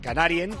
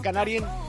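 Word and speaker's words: Kanarien: Bienvenidos desde ahora y hasta la una y media de la madrugada, cuando sean las 12:30 en Canarias, en Canarien. Los alemanes dirán Canarien. Canarien, ¿cómo se dice Canarias en alemán? Canarien.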